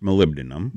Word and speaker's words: molybdenum: It's actually pronounced molybdenum. – Molybdenum. Molybdenum. – But because I'm a child of the 60s, I have always called it molybdenum. – Oh, good golly, Miss Molly. – Yeah. – All right. Molybdenum. What is molybdenum? – It is a trace mineral, and if you give me a second, I've got it.